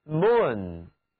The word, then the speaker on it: moon: 请你们来看，月亮是 moon， 2个字母 O 合在一起读作长元音 U， moon 月亮。